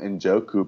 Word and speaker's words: Njoku: Hilton and Odell Beckham Jr. as the receivers, David Njoku at tight end. Is that a silent N? I'm not sure. Anyways, Chargers defense and Kaimi Fairbairn as the kicker. – No, I believe everyone calls him Njoku,